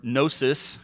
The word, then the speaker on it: gnosis: And the Greek word, we talked about last week, the Greek word gnosis has a silent G at the front of it, so it's not gnosis, and it means knowledge.